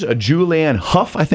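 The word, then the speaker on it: Julianne Hough: Julianne Huff. Yeah, that's how you say her name.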